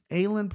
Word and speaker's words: Aylin: And then Alan Perez, Aylin Perez. Yeah, I think it's probably Aylin. Aylin Perez, there you go. You finally said the name right, Brian.